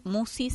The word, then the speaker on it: Musci: En la semana subimos el programa filmado al canal de YouTube que lleva mi nombre, Marisa Musi. Musi se escribe M-U-S-C-I.